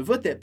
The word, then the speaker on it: votaient: Então, les femmes ne votaient, com ENT, que a gente não pronuncia, ne votaient pas.